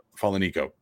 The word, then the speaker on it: Falanico: And like he said, the guys that are transferring in here, Paul Moala, Giuliano Falanico. I said that right. Falanico. Bingo.